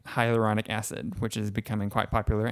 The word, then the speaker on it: hyaluronic acid: She taught us all how to say it. Hyaluronic acid. – Yep, that's it.